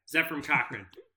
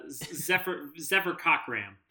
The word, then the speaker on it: Zefram Cochrane: I should have mispronounced it. You know, Zephyr Cochram. That guy.